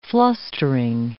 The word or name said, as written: flustering